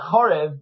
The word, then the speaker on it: Chorev: The good thing about Chorev, which is unique, I've got it written here, H-O-R-E-B, Chorev, right, but it's actually pronounced Chorev, is that what he does is he shows the unity of halacha and philosophy, Jewish law and philosophy.